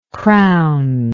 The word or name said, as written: crownless